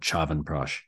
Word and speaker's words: chyawanprash: I've learned the way that I learned how to say it was called chyawanprash, and this is a formula of several different herbs.